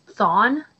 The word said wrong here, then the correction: Thrawn: General Thrawn. – Yep. Thrawn. Thrawn. Admiral Thrawn.